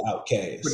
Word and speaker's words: Outkast: Pronounced Outkast. O-U-T-K-A-S-T.